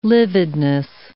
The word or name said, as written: lividness